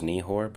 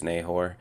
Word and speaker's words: Nehor: You have Sherem, Nahor, I believe is how you pronounce it. I always thought it was Nehor, but I'm pretty sure it's Nahor.